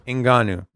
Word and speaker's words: Ngannou: Heavyweight champ Francis Nanu? Ngannou. – How do you say that? – Right. Ngannou.